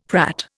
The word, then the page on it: prat: Indian